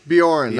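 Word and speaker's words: Beorn: You just didn't pronounce bear right. – Is that Bjorn? – Bjorn. That's right.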